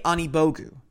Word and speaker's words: Anigbogu: It is Ike Anigbogu. Ike Anigbogu.